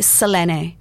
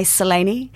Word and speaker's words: Selene: Because it's pronounced differently in Greek. – Well, I don't know that I'm going to pronounce it perfectly, but it would be Selene. – Selene. – But with a bit of a UK and Oz twang, we say Selene.